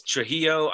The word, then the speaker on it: Trujillo: I'm sure it's Trujillo. I'm I'm very sure that when I saw Metallica with him, they they pronounced it like that. But he's fine. from Mexico City. – All right, okay, yeah, the double L is pronounced that way.